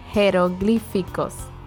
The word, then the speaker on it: Jeroglíficos: But you do pronounce it heh. – Jeroglíficos. – I thought the H goes silent. – No, because it's written J. – Oh, in Spanish it's written yeah. with a J.